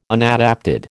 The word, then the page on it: unadapted: American English